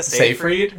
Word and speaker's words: Seyfried: Amanda, Siegfried, something like that. Help me so he actually pronounces the woman's name correctly. – No, I'll leave you hanging. I don't know, Amanda Seyfried, right? – She's in Les Miserables.